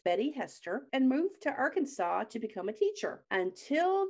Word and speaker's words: Arkansas: So he just basically went on with life and married his college sweetheart, Betty Hester, and moved to Arkansas to become a teacher until the school. Yep. – You said Arkansas? It says Arkansas. – Yes. Here in America, we pronounce that Arkansas.